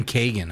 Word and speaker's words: Keoghan: Barry Keoghan, or Kogan, The Banshees of Inisherin. – How do you say that? Is it Keoghan? – I like that. Keoghan.